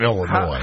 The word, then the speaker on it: Illinois: Which some people improperly pronounce Illinois, by the way. – No, not Illinois. Illinois. – Where's the Y there? Where do you get Illinois from? Ois? There's an S at the end.